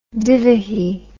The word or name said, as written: divehi